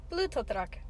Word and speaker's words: Blutdruck: It's pronounced Blutdruck.